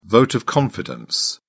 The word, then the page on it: vote of confidence: British English